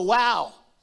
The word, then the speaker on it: Wau: He said, yes, there is, but it's not spelled W-O-W. It's spelled W-A-U.